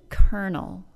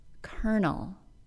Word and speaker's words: colonel: H-A-L-F. Folk. F-O-L-K, and yolk, Y-O-L-K, as in the yellow part of an egg. The word kernel, spelled C-O-L-O-N-E-L, has one of the least intuitive pronunciations we have in modern English, which makes it kind of a fun word in its own right. The O-L-O part of the word is simply pronounced as schwa plus R, giving us kernel.